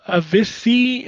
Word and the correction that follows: Avicii: I believe that was pronounced Avicii. Avicii, yeah.